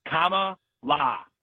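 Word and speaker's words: Kamala: It's comma la. Comma la. The Sanskrit word for lotus, which is a beautiful name.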